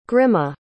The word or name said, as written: grimme